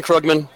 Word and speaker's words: Krugman: I did say your last name correct, right? – Krugman. Okay, whatever you got. – Great. I appreciate it. Well, okay. – I typically rhyme it with sugar, so Shug Krug.